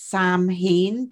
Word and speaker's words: Samhain: I know it's confusing because it's spelt S-A-M-H-A-I-N, like you would say it's Samhain, but it's Samhain. – And they spell it here S-A-M-H-U-I-N-N, which looks like Sam.